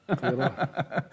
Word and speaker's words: Qirat: the Qitrat of what I mentioned. – Qirat. – I keep saying it wrong, Shaykh. I'm not sure why. – Qirat.